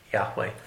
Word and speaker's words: Yahweh: His name is there, and his name is Yod Heh Wav Heh, pronounced as Yahweh. In the English, the H almost sounds silent. If you just say Yahweh, it's Yah. You have to pronounce it. You have to pronounce the H, but that's the way his name is.